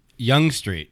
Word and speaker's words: Yonge Street: Young. Why is it spelled like that? – It's Y-O-N-G-E. – I'm glad you say this. And my buddies at home are going to get a good, because we call it Yonge because it's funny to say that. So it's Young Street. It's Young Street, Y-O-U-N-G. This is spelled Y-O-N-G-E. And it's just like one of those things like in New York, how you have Houston Street because it's named after a guy named Houston.